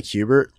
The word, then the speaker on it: Kubert: They say Kubert. – Kubert, all right. – Everybody does the same thing. It's Kubert or Kubert.